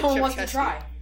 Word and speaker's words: Krzyzewski: Yeah, I know, but you pronounce it Krzyzewski.